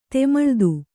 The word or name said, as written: ತಮಿಳಿತ್ತಿ